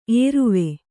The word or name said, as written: ಏರುವೆ